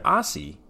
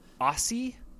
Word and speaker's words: Aase: Can you think of something that rhymes with Aase? – The closest I can get is Yahtzee.